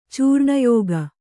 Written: ಚೂರ್ಣಯೋಗ

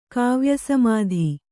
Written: ಕಾವ್ಯಸಮಾಧಿ

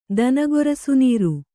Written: ದನಗೊರಸುನೀರು

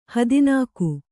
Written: ಹದಿನಾಕು

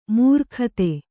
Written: ಮಾರಿಗುತ್ತ

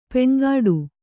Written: ಪೆಣಗಾಟ

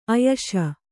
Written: ಆಯುಷಿ

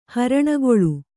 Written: ಹರಣಗೊಳ್

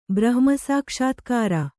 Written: ಬ್ರಹ್ಮಸಾಕ್ಷಾತ್ಕಾರ

